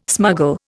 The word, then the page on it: smuggle: Indian